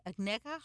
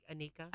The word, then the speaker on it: Agneica: A-G-N-E-I-C-A. Agneka? How do you say that last name? – Anika. – Anika.